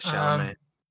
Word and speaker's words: Chalamet: Yeah, Chalamet.